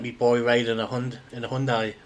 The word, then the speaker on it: Hyundai: Did you know that that's not how you pronounce it? – I've heard people say Hyundai. – It's Hyundai. Hyundai.